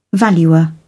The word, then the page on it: valuer: British English